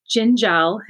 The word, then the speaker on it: Dziendziel: and it's spelled d-z-i-e-n d-z I E L. So it's quite intimidating when you see it written down, but when you learn it, it's it's easy because it just repeats itself.